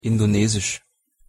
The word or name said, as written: Indonesisch